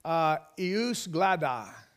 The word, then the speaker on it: jus gladii: If I were to say, eus gladi, eus gladi, they would know what that was. In English, it's spelled J-U-S, G-L-A-D-I-I.